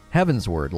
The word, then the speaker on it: Heavensward: How do you say that? Heavensward, like upward? Yeah, I got it.